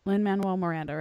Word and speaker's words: Lin-Manuel Miranda: Lin-Manuel Miranda, right? – Yeah.